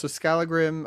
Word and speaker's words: Skallagrim: Skallagrim. That's the way I say it.